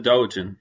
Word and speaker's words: doujin: how's that pronounced? – A doujin.